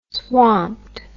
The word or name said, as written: swamped